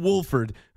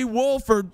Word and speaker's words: Wolford: Uh, yeah, John Wolford, who nobody even knows his actual name, and he dodges the own his own pronunciations. Like, yeah, you kind of say the L.